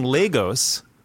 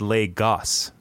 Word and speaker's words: Lagos: I've been saying Lagos, like go, but then last night I looked it up and they said Lagos, not ghost.